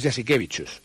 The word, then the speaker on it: Jasikevicius: Sí, es igual que el entrenador del Barça, que es se escribe Jasikevicius, le llaman Jasikevicius, ¿vale? Pero es Jasikevicius. – Ah, vale. – Eso es el lituano.